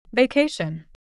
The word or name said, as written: vacation